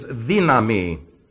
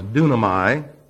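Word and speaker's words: dynamai: The second word that is also translated power is the Greek word ischis, and that's I-S, H-E-E-S, is his. The third word is dynami. You probably heard it pronounced dunamai. That's sick. That's not the way the Greek language is spoken. Dynami. And that's D-Y-N-A-M-A-I. Dynami.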